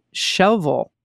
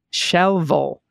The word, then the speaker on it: Shelbyville: The city is just north of the town of Shelbyville. Oh, pronounced natively as Shelville. Shelville. Shelville. Okay.